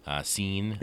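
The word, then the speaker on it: Cine: They like their safety, you know, the safety depth with Smith and Metellus and Seen yeah. – coming back from his, I think that's how you pronounce it. – Yeah, that's right.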